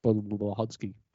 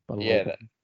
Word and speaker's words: Budenholzer: Bud's son, Mike Budlodzki. – Budenholzer.